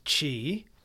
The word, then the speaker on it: qi: Pronounced qi, qi is a Chinese word that basically means energy.